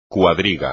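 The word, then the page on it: cuadriga: español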